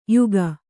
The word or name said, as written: ಯೋಗಿ